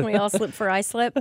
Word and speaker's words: Islip: Oh, you mispronounced it. It's Islip.